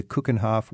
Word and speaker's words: Keukenhof: Keukenhof. – Keukenhof. – No, Keuken. The Keuken is a kitchen. – Kitchen, okay. – So it's like a kitchen's garden.